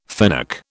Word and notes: fennec: British English

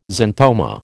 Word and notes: xanthoma: American English

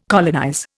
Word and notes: colonize: Indian

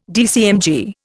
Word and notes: DCMG: Indian

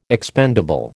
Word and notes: expendable: American English

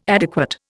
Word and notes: adequate: Indian

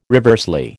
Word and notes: reversely: American English

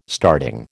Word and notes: starting: American English

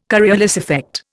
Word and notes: Coriolis effect: Indian

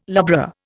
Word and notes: lubra: Indian

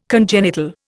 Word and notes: congenital: Indian

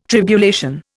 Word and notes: tribulation: Indian

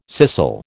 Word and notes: scissel: American English

